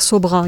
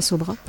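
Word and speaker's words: Sobhraj: Alors, je vais vous parler d'une série qui s'appelle Le Serpent, qui est l'histoire de l'escroc Charles, je sais pas comment ça se prononce, je veux dire Sobra, il y a un J à la fin, mais voilà, on l'appelait Sobra.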